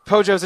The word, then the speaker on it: Pojo: Pojo's a chicken. – Oh my god. – I think it was supposed to be Poyo, and they decided to spell it P-O-G-O. P-O-J-O. That's Pojo, not Pogo.